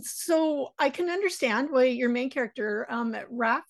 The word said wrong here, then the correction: Rafe: is that how it's pronounced? – Rafe.